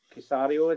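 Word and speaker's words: Caserio: Even like Cesare, I always get his name wrong, Nick Casario, is it? – Yeah.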